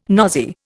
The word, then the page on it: nosy: Indian